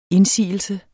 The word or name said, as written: indsigelse